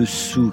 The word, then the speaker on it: Saou: Je vous ai parlé de forêt magique, donc au menu, cette forêt de Soux qui s'écrit S-A-O-U,